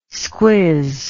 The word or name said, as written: squiz